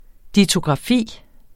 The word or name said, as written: dittografi